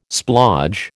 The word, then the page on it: splodge: American English